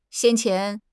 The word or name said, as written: 先前